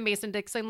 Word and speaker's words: Mason-Dixon: Like, if you, I feel like if you live north of the Mason-Dixon line, you probably, Mason-Dixie line, sorry. – No, you said it right the first time.